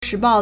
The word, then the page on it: 时报: 時報; shíbào